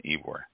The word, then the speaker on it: Ybor: And for those of you out there in the listening world, Ybor City, and it's spelled Y-B-O-R, is pronounced Ybor.